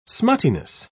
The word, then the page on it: smuttiness: British English